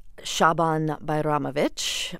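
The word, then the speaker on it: Šaban Bajramović: This new album, you are performing works by a musician named Shaban Bajramovich. I hope I said that right that time. – Perfect.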